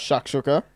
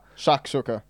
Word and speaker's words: shakshuka: On baked eggs, shashushka, if I've said that right. – Shakshuka. – Shashuka. – Shakshuka.